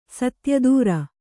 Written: ಸತ್ಯದೂರ

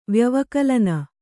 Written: ವ್ಯವಕಲನ

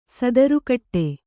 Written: ಸದರಗೊಡು

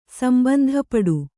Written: ಸಂಬಂಧಪಡು

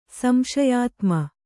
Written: ಸಂಶಯಾತ್ಮ

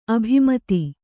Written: ಅಭಿಮತಿ

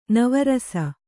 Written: ನವರಸ

